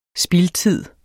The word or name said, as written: spildtid